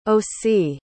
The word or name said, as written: osee